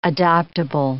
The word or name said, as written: adoptable